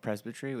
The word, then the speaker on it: presbytery: I think, oh my gosh, it like rocked my world that you said that because I went to the, is it presbytery? How do you say it? – Oh, presbytery,